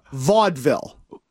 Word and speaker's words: vaudeville: Okay, so you think it's Valdair, like a city or a town? – It's an act. – Okay, so, okay, it's pronounced Vaudeville. – Oh. – It's like, your place is like Hidden Valley.